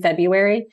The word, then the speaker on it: Febuary: Yeah, they can just um, they can go hit up my personal account, Megan Febuary. It's F E B U A R Y. My last name is spelled different. I know it's confusing, um, but you'll find me there.